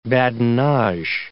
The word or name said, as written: badinage